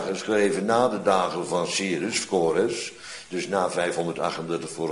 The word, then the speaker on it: Cyrus: Maar je weet inmiddels ook dat er een figuur verschenen is onder de naam die Cyrus, wat je heel gemakkelijk als Kores kunt uitspreken, onder de CK is en andersom. En die Y van Cyrus, ja, die wordt uitgesproken met een als op door de Comri. Die je schrijft als Kimri. En het wordt uitgesproken als een U. Door alle Duitsers en Denen in elk geval. Meer weet ik niet. Zodra er een Y staat, zeggen zij U. Dus Kores en Cyrus, ja, dat is heus precies hetzelfde.